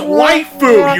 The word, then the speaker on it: waifu: It's, first of all, it's pronounced waifu, you dumbass. Waifu.